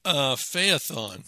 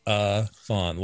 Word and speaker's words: Phaethon: Say it again for me. – Phaethon. – Phaethon, like telethon. – Yes. – Except it's Phaethon. All right. Now Phaethon. I'll remember.